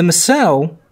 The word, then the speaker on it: Mosuo: The Masao, and that's spelled M-O-S-U-O, make up one of the 56 minority groups in China.